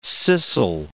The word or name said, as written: scissile